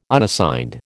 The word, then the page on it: Unassigned: American English